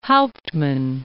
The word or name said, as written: Hauptman